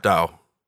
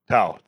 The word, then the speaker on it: Tao: Tao. – Tao. T-A-O. – It's pronounced Tao.